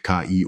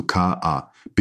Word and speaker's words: KI: Ausgerechnet das Wort KI spricht mein Avatar falsch aus, nämlich als Ki. Dann muss man die Aussprache verfeinern. Einfach das Wort KI markieren und lautmalerisch neu schreiben, also K-A-I.